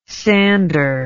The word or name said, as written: sandor